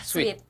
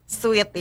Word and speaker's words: Sweet: Ya, sweet atau sweat nih bacanya? – Sweet. – Sweet ya. Oke.